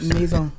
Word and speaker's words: Maison: Nah, it's too many syllables. It's Maison.